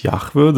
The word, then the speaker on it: Jahwe: Es gibt dazu andere, also er wird eigentlich in unseren Kreisen wird er so ausgesprochen. Im Hebräischen würde ich sagen, heißt es Jahwe, das sind dann diese JHWH, diese vier Buchstaben.